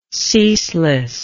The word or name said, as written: ceaselessness